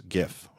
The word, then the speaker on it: GIF: Apple, the narrator, young woman, pronounces the word GIF as GIF, which is how I pronounce it. Is it, you know, the GIF image format? Is it a soft G or a hard G? And Apple has come out strongly on the hard G side, which is where I've always been.